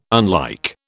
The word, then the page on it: unlike: British English